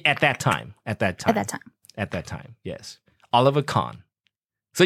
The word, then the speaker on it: Kahn: Oh, it's not a Carmen, it's a Kahn. – Oliver Kahn. – Oh, that's a German pronunciation, Kahn, not Carmen.